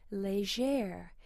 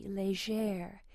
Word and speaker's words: Légère: My name is Légère. And some people are not used to that soft G, Légère.